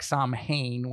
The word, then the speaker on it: Samhain: Looks like Samhain when you see it, but it's an old Gaelic word pronounced Samhain.